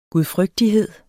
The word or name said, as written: gudfrygtighed